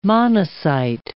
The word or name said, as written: monocyte